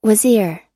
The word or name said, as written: wazir